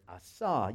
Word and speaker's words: asah: He uses the word asah. You would spell it A-S-A-H.